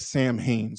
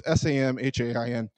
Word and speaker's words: Samhain: Many Christians believe that what we call Halloween started with a Celtic festival called Samhain, but it's spelled like Samhain. So it's Samhain, but it's pronounced Samhain.